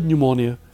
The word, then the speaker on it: pneumonia: the word pneumonia can be a tough word to spell because the P is silent.